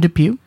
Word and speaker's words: Dupieux: Quentin Depew.